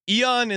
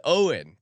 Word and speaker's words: Eoin: I mean, it appears to be a word that would rhyme with groin. – Yes. So, oin. – Pulled a Eoin. Oh, he says Owen.